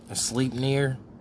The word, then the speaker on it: Sleipnir: Pronounce it like reindeer. – Sleipnir. – Sleip. Like rain.